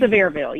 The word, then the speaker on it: Sevierville: So we're here in, now I know we're going to say this wrong, but is it Sevierville, Tennessee? – Sevierville, yes.